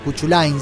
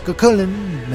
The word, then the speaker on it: Cuchulainn: Cuchulainn se escribe, pero es Cuchulainn, me dicen que se pronuncia, y está compuesta nada menos que con partes distintas de arreglos tradicionales o de tonadas tradicionales.